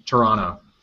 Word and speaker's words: Toronto: I stole the Canadian pronunciation, Toronto.